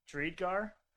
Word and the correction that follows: Driedger: You, was he on the Panthers last year? Was he that goalie that was on the Panthers last year? – Yes. – What? How do you say his name? – Driedger. – Close. I was close.